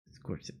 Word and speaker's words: Scorsese: Scorsese. – I say it different. – No, you said it right.